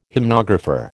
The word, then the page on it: hymnographer: American English